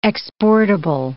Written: exportable

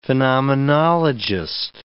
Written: phenomenologist

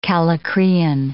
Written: kallikrein

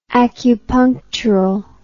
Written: acupunctural

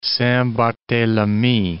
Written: Saint Barthelemy

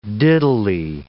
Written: diddly

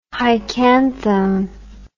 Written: hycanthone

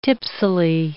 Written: tipsily

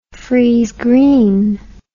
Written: friese greene

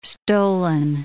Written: stolon

